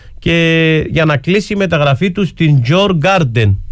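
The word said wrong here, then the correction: Djurgården: Έτσι προφέρεται, με πήρε τηλέφωνο ο θείος. Σήμερα ο θείος, η σύζυγός του είναι από τη Σουηδία, μου το είπε, αλλά πάλι το ξέχασα. Τέλος πάντων. – Γιουρ Γκόρντεν. – Γιουρ Γκόρντεν, Τζορ Γκάρντεν.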